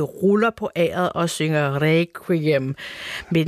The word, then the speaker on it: requiem: Der siger man requiem med KV, ligesom på dansk, og der er mange andre lyde, der bliver udtalt anderledes.